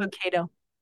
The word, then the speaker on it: Lucado: Lucado. Max Lucado. – Lucado, yeah.